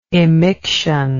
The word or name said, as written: emiction